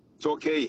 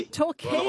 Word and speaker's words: Torquay: In Talk, is it? – Torquay. – Torquay. – Well, like Torquay.